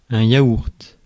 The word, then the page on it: yaourt: /ja.uʁt/; France